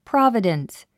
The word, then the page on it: providence: prάvədəns; プロォビデンス